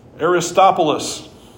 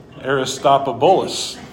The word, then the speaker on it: Aristobulus: Greet those who belong to the family of Aristobulus. Nope, I said that wrong. Aristobulus.